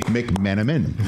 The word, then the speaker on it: McMenamin: Dave McMenamin.